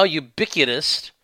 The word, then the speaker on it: ubiquitous: Number 5, Texas has led the country against the now ubiquitous rhymes with iniquitous, LGBTQ agenda.